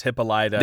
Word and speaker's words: Hippolyta: How do you say her name? – Hippolyta. I prefer to say Hippolyta, but they do say several times it's Hippolyta.